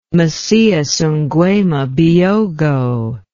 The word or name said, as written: macias nguema biyogo